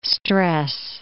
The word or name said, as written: stress